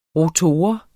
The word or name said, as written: rotor